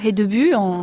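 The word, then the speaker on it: Hedeby: Racontez-nous, Lucie Malbos, le port d'Edeby, c'est comme ça qu'on prononce. – Oui, Edeby pour les Danois.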